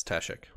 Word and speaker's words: Tashikk: We're in Ta- Tashikk. Is that how you say it? Tashikk. Yes, Tashikk.